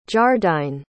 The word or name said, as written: jardine